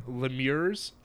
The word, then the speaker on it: lemures: He's like, yeah, so there are things called ghosts, and, like, they're lemures is how they pronounce it.